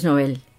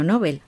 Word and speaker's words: Nobel: extraterrestres o de premios Nobel, que se pronuncia así y no Nobel,